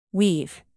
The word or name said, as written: weave